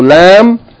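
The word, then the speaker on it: la (لا): So lam, fatha, alif is pronounced la.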